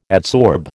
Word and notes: adsorb: American English

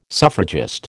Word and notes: suffragist: American English

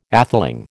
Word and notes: atheling: American English

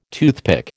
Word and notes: toothpick: American English

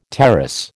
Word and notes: terrace: American English

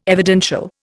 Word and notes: evidential: Indian

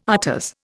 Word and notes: afters: Indian

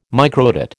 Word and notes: microdot: American English

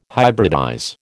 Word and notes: hybridize: American English

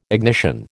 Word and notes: ignition: American English

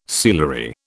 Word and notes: sealery: British English

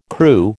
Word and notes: Kroo: American English

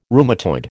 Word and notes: rheumatoid: American English

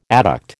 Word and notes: adduct: American English

